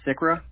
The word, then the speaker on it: Cikra: Sikra, and it's spelled C-I-K-R-A.